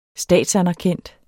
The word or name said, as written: statsanerkendt